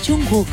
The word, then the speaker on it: Jungkook: Es porque lo pronuncias mal. – ¿Es Kok? – No, es Jungkook. – Ah, Jungkook. – Hook. – Hook. ¿Cómo? – No es K de K, es como de la garganta.